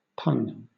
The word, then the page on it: pun: British English